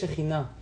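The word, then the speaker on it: Shejina (שכינה): la Shejina, que es Shejina. Shejina se escribe Shohen Yut He, que Kadosh Baruchu posa y se ve claramente entre nosotros.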